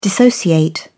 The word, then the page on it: dissociate: British English